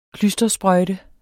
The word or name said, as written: klystersprøjte